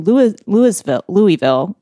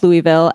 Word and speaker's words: Louisville: She then traveled to Louisville. Why do I not know how to say that right now? – Because there's an S in it.